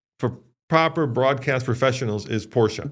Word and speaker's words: Porsche: I get your argument, the vernacular is that because that's how it's pronounced in its home country, right? That's how Porsche is pronounced in Germany.